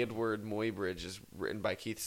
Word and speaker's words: Eadweard: Our current catalog includes Slotzer, Helios, early photographs by Edward Muybridge. His name is spelled E-A-D-W-E-A-R-D. Edward. It's like...